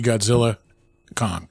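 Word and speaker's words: Godzilla x Kong: Get your ass to the theaters and see Godzilla Kong. I almost said the X. Not supposed to say the X. – X is silent.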